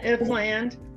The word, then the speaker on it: Ifland: Ifland.